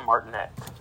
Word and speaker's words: Martinet: Charles Martinet. – Martinet.